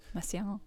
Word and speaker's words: Messiaen: But Messiaen. – Yeah, you speak French. It's Messiaen. Okay.